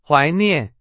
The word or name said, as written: 怀念